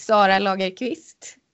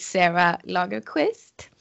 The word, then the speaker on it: Sara Lagerqvist: My name is Sara Lagerqvist in Swedish. You might want to do an English twist on it and call me Sarah Lagerqvist.